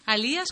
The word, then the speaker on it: Alías: Y no estaba seguro si era Elías. – No pasa nada. – O Alías, Elías, Alías. – Y al final, ¿cómo es? – Alías con acento en la I.